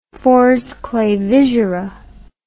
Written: Fors Clavigera

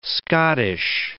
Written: Scottish

